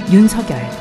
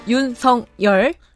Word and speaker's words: 윤석열: 기호 2번 국민의힘 윤석열 국민의힘 윤성열 이렇게 발음하면 됩니까? – 윤석열. – 윤석열. – 지금 윤성열이라 하셨어요.